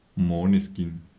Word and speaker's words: Måneskin: La band Maneskin in realtà viene pronunciata Moneskin.